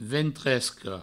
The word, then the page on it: ventresca: ventresco